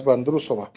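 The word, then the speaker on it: Vondroušová: Con respecto a las mujeres, la checa Markéta Vandrusova, pero creo que se pronuncia más Vandrusova.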